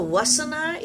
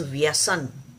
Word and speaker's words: vyasan: Now the vasana is not a correct pronunciation of the real word is vyasan. The real Sanskrit word is vyasan.